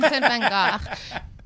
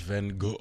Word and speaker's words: Van Gogh: Say it like you were Dutch. – Van Gogh. – Van Gogh.